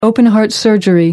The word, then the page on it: open-heart surgery: American English